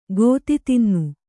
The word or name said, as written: ಗೋತಿತಿನ್ನು